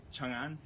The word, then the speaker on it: Chang'an: How do you say the Chinese city, C-H-A-N-G-A-N? – Chang'an. – Say it again.